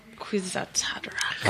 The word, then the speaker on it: Kwisatz Haderach: He is the Kwisatz Haderach. – Yeah. – I don't know what that means. All right. – You read Dune? – I know. I'm kidding. I can never pronounce it. – Kwisatz Haderach.